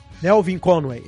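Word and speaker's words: Melvin Conway: Então, eu, eu, eu citei no começo aqui o, o, lance da Lady Conway, né? Que ela, que ela, eu talvez esteja falando errado o sobrenome do carinha aqui, se vocês podem corrigir aí. Nelvin Conway, é isso aí.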